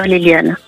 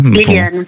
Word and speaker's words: Lilian: Alors, justement, pour la consonance de votre prénom, je l'ai bien précise, je l'ai bien prononcé, hein, c'est bien Lilian. – Oui, ça s'écrit comme ça. – Ah, ok. – Mais vous pouvez dire Liliane. – On vous appelle Liliane ou Lilian ? Peu importe. Hein, Liliane. Pour...